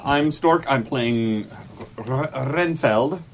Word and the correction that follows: Renfield: Renfeld. Field. You spell it Field. Renfeld. – It is Field. – Renfield.